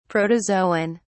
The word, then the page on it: protozoon: British English